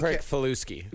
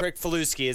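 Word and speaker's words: Kricfalusi: How do you pronounce it? John Crick Faluski. – Yep. – Crick Faluski. It's an interesting last name.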